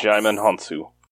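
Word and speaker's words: Djimon Hounsou: Jaimon Hounsou? – Yes. – Jaimon Hounsou.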